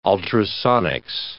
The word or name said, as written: ultrasonics